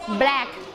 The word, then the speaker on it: black: Back เฉยๆได้ไหมไม่ได้ต้องออกเสียงว่า black